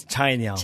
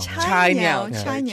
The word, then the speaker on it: Cainiao: Is he saying that correctly? – I think it's Cainiao. – Cainiao. Yeah. All right.